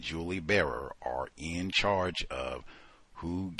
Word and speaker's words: Barer: Uh, it looks like Colson Whitehead's wife, uh, Julie Barer, I think is how you say her name, B A R E R, Julie Barer.